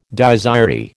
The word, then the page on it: Dies irae: American English